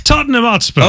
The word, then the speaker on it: Tottenham Hotspur: Tottenham Hotspur. – Okay, that's yes. You said it right.